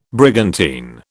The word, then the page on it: brigantine: British English